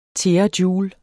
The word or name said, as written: terajoule